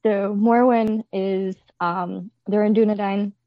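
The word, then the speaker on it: Dúnedain: Okay, so Morwen is a, correct me if I'm saying this wrong, Dunedain? I can't pronounce Dunedain.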